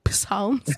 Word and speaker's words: Psalms: No one has actually really come back to tell me why the P is silent, but Asikolapa, it's going to be a very long quarter because it's all about the Psalms. Psalms.